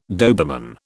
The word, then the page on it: Doberman: British English